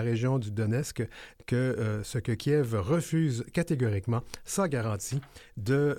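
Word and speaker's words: Kyiv: Pour marquer ce jalon, la présidente de la Commission européenne, Ursula von der Leyen, est à Kiev, on dit Kiev, je pense qu'on faudrait dire Kiev, on le prononce comme il faut en ukrainien, pour réitérer le soutien indéfectible de l'Union.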